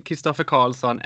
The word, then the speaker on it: Kristoffer Karlsson: Marry Me was composed by you, Erik Nyholm, Kristoffer Karlsson and Jessica Lundström. And I guess here I gave them very Swedish pronunciations of the names. – Yep, that's true.